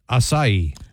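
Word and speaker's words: açaí: I looked at the pronunciation of ASEI. So this is, even the pronunciation doesn't help me. I'm going to play it to see if this helps. – Acai. – That sounds right.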